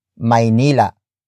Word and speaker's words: Maynila: And in this case, it's Maynila.